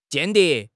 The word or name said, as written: 间谍